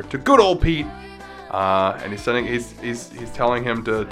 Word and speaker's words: Piet: Zwarte Piet, or Pit. It's like spelled P-I-E-T.